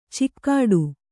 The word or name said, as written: ಚಿಕ್ಕಾಡು